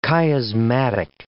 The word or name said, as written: chiasmatic